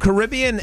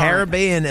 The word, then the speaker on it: Caribbean: Caribbean is the more common pronunciation in British English and used by major news organisations. But right. Caribbean is American English and refers to is used in the Pirates of the Caribbean.